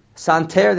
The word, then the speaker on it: santer: Much made it a santer. How do they say they pronounce this word? – Santer. – Santer, they say santer.